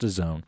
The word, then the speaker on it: DAZN: Yes, it's pronounced DAZN.